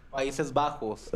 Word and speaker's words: Países Bajos: Países Bajos.